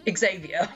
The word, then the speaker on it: Xavier: Look, I've watched enough X-Men. It's Xavier.